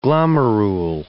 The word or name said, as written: glomerule